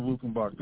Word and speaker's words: Luckenbach: It's spelled L-U-C-K-E-N-B-A-C-H, Luckenbach.